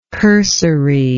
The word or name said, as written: cursoriness